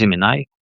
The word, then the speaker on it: Gemini: Нет, мы используем то, что нам еще Google предоставил в декабре 2024 года, Gemini, или, как правильно говорить, Gemini.